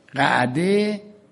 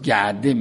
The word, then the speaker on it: قعده: قعده که بعضی عربا در تلفظ گعده میگن، یعنی حلقه نشستن.